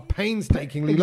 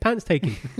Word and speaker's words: painstaking: Like, so Kedge, the way Kedge tells stories sometimes are painstakingly long. – Exactly. – How would you say it? Pantstaking. – But I just discovered this week that it's actually pains taking.